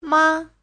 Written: ma5

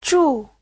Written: zhu4

